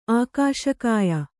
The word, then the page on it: ಆಕಾಶಕಾಯ: ākāśa kāya